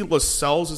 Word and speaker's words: Lascelles: You can see Lascelles, is that how you say his name? – Yeah.